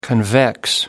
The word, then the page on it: convex: American English